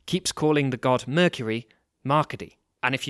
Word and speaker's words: Marcade: But the accent on the E of Marcade's name would make his name sound more like Marcady.